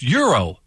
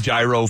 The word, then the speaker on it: gyro: It's like you're pronouncing gyro wrong. It's euro. – I've been saying gyro for 35 years. I don't know. – I know. I see a G. I'm saying gyro.